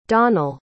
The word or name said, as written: donnel